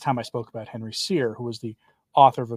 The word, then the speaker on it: Sire: And it's by Henry Sire. Yes, that's how I heard him pronounce his own name in his own in a speech he gave that was put up in podcast form.